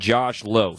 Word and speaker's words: Josh Lowe: Remember, it's Brandon Lau and Josh Lowe. Spelt the name, spelt the same, pronounced differently.